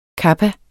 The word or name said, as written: kappa